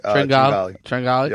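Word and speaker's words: Tringale: Kevin Na, Kristen Triangle, or whatever. How do you say it? – Yeah, Tringali.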